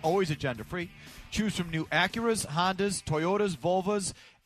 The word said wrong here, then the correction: Volvo: Did I mispronounce two brand names? – You did. – There? – It's a Volvo and not a body part, and it's an Audi.